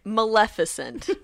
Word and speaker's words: Maleficent: So then moving on, we have, and I'm gonna pronounce this correctly, unlike most of the people around, Maleficent.